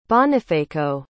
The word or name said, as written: Bonifaco